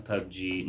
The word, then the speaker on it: PUBG: Yeah, it's pronounced PUBG.